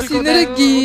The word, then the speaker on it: синергия: Да, синергия. – Ударение на синергия.